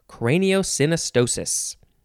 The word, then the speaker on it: craniosynostosis: Next is craniosynostosis. Is that how you say it? Synostosis. Yes, this is all one word. Craniosynostosis.